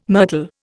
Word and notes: myrtle: Indian

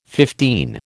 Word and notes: fifteen: American English

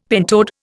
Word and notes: pentode: Indian

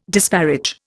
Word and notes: disparage: Indian

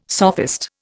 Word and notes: sophist: Indian

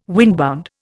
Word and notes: windbound: Indian

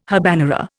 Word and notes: habanera: Indian

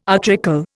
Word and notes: utricle: Indian

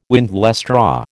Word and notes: windlestraw: American English

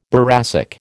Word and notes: boracic: American English

